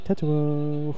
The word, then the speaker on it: Tetsuo: You have to say it like the soundtrack is a Tetsuo.